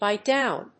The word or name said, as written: buy down